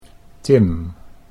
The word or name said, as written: Tim